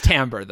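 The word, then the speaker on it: Timbre: At that point, Timber has left Eshenai and is starting to follow Venli. Kind of. – Yes. It is Tambor, though. I looked up the pronunciation, unfortunately.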